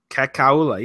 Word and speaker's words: Kekalua: He will never come to the show again because A, we screwed it up, and B, you cannot pronounce his name. – Robert Kekalua. – Kekalua. – Kekalua? – Kekalua, yes.